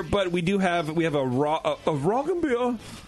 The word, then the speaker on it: Roggenbier: We're debating on how to say it, and it's like, just run it together. Oh, yeah, have a Roggenbier. – Roll the R and say it like Dieter.